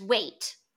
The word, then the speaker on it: Waite: So there's Wait, W-A-I-T-E.